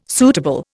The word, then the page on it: suitable: Indian